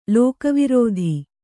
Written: ಲೋಕವಾರ್ತೆ

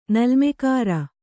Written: ನಲ್ಮೆಕಾರ

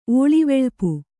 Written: ಓಳಿವೆಳ್ಪು

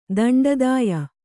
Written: ದಂಡದಾಯ

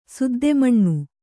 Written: ಸುದ್ದೆಮಣ್ಣು

